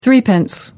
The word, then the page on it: threepence: American English